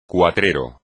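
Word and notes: cuatrero: español

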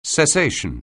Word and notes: cessation: anglais

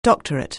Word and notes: doctorate: anglais

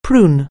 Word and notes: prune: anglais

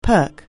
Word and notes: perk: anglais

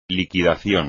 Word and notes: liquidación: Spanish

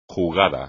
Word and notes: jugada: Spanish

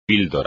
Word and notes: píldora: espagnol

